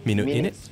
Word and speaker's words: Minute Init: Yeah, how do you pronounce that, actually? – Minute Init? – Minute Init. Like, in it, bro. Like that. Like a London slang or something.